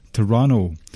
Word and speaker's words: Toronto: But what is also next weekend is the NXT TakeOver Toronto, or should I say, as far as I'm aware, the correct pronunciation is Toronto. They like to have a softer T, second T in that word,